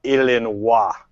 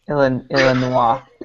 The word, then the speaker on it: Illinois: I'm from that state, and you have to realize that the word, as we pronounce it, Illinois, is actually French. So they prefer you to pronounce it Illinois. – Yes, please. – Illinois.